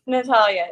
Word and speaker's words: Natalia: Everybody, this is Natalie Sordano. Am I saying it right? – Natalia, yeah. – I said Natalie.